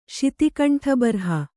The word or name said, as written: ಶಿತಿಕಂಠಬರ್ಹ